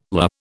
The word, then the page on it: lah: British English